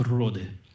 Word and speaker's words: Rode: Пишется как Rode. R-O-D-E. – Понятно. – Rode Island.